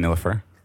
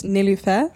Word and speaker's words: Nilüfer: How's it going, Nilüfer? – It's good. – Am I saying that correctly? – Yeah, almost. Nilüfer.